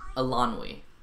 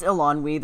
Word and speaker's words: Eilonwy: No, it's Alani. There's a W in there. – There is a W, but I'm not sure if it's silent or not. – I think it's we. Because it's spelled E-I-L-O-N-W-Y.